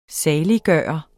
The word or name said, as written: saliggørende